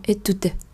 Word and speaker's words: étude: Chúng ta không đọc étude, étude, étude hoặc là étude hoặc là étude, étude. Không, chúng ta sẽ đọc là étude tud tud các bạn phải có một khoảng lên rồi sau đó mới xuống